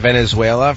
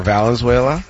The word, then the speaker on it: Valenzuela: Oh, you've gone Venezuela for Valenzuela? – Many times. Many times live on radio.